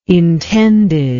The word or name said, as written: intended